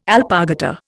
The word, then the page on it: alpargata: Indian